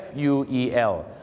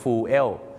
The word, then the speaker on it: fuel: คำว่าเชื้อเพลิงครับ fuel บางคนนะครับก็ออกเสียงว่า fuel